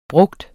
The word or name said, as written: bruger